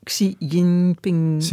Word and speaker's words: Xi Jinping: Xi Jinping. – Ja. – Is dat het goede, de goede uitspraak? Zo Mandarijns, ongeveer, ja.